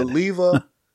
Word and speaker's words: Oliva: Is it Aliva? – Oliva. Like Olivia. – Oh, okay. – The I after the, before the A. So it's Oliva.